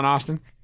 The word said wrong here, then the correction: Astin: Did you pronounce it Astin? – It's Astin. Yeah, Astin.